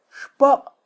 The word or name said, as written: xpoq